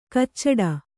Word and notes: ಕಚ್ಚಡ: kannada

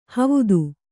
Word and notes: ಹವುದು: havudu

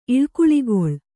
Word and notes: ಈಳ್ಕುಳಿಗೊಳ್: īḷkuḷigoḷ

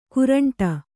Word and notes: ಕೋರಂಟಿ: kōraṇṭi

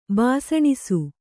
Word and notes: ಬಾಸಣಿಸು: bāsaṇisu

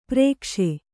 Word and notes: ಪರಿಘೋಷ: Kannada

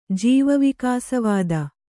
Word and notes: ಜೀವವಿಕಾಸವಾದ: jīva vikāsa vāda

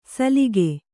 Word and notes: ಸಲಿಗೆ: salige

